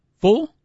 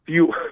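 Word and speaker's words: Fewell: Fuel. Yes. I think that's how you pronounce it. – No, seriously. How do you pronounce it? – Fuel, as in like the fuel that you put in your car.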